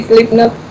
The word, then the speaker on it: Slipknot: pero ¿cómo se pronuncia Slipknot? ¿Algo así? – Sí, Slipknot.